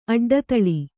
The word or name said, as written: ಅಡ್ಡದೊಲೆ